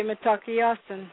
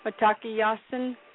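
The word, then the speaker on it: Mitákuye Oyás'iŋ: That's why we say Matakiyasan, all my relations. – Oh, man. How do you say it again? – Matakiyasan, Matakiyasan, all my relations.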